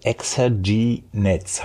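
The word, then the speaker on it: Low-Exergie-Netz: Ein weiteres Stichwort, was ich gelesen habe, wo ich Probleme habe, mir da irgendwas drunter vorstellen zu können, Low Loexerginetz. Habe ich es überhaupt richtig ausgesprochen? das ist richtig ausgesprochen, ja.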